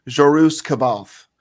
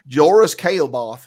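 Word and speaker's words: Jorus C'baoth: When I was reading it, I always used to call him Jorus Kaelbath. That's what. – That's the southern version. – Oh, yeah. And then I realized that I was like, no, this is not right. So I started trying to pronounce it correctly.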